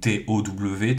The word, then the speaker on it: Tau: Et du coup, c'est ce qui va se passer avec la création des Tau, qui sont d'abord connus sous le nom des Tao, Tao. Et ensuite des Tau, Tau. D'ailleurs, les Anglais prononcent Tau, donc Tow techniquement, mais bon, voilà, ils l'écrivent comme ça, comme la lettre de l'alphabet grec.